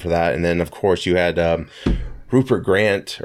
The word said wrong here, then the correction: Grint: Or is that, say that right? – Rupert Grant. – Grint. From Harry Potter.